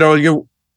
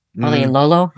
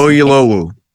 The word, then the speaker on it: Oyelowo: John Hamm for Fargo, David Oyo. I never say his name right. Oyo. – Oyelolo? I don't know. I can't say his name. Oyelowo. – Okay. – I believe.